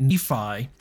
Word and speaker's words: Nephi: I think that's why we're getting such a translation of when we get Nephi, because there's an indication that the Hebrew P is phonetically equivalent to an F, N-F-Y, Nephi. So much like in our English spelling, P-H.